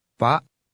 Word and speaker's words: Pak: Pak. – The K on the end of that word is not pronounced, and the A immediately before it is cut off sharply.